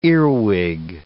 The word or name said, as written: earwig